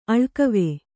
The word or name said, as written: ಅಳಿಗವಿ